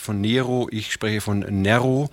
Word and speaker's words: Nero: Zu Neros Zeiten hieß der Mann Nero, also kurzes E. Inzwischen ist es üblich, den betonten Vokal in einem Wort im Deutschen zu längen, also wird Nero draus, so wie Forum, das eigentlich Forum heißt.